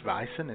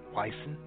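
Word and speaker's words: Weizen: And Weissen, which is spelled with a W and not a V, but it's pronounced Weissen and not Weissen, it means wheat.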